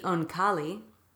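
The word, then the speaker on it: Oankali: See, these aliens, the Onkali, you good with that pronunciation? – That's exactly how I do it.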